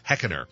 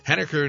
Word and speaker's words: Henniker: She used to pronounce it Heckener. Henniker, New Hampshire to take on the New England College Pilgrims.